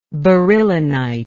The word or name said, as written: beryllonite